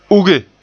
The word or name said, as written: uge